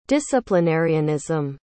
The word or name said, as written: disciplinarianism